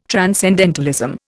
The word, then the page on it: transcendentalism: Indian